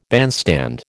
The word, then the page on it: bandstand: American English